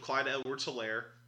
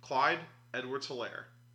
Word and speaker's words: Clyde Edwards-Helaire: All right, outside of the first round selection of Clyde Edwards-Helaire, that's how you pronounce his name, everybody. Clyde Edwards-Helaire. No one knows how to say this guy's name. It's super simple.